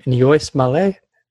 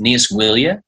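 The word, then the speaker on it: Níos moille: But it's an Irish phrase pronounced Nias Willia. – Okay. – And you slow it down to get it phonetically, but you want to say it as fast as possible.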